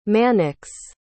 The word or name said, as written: mannix